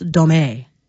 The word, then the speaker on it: domme: So dom, D-O-M, is a label that is given to a male dominant, whereas dom, D-O-M-M-E, sometimes pronounced domé.